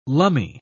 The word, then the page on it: lumme: British English